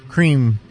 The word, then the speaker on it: crime: Cream being spelled like crime, but pronounced cream if you listen to the way they were singing it.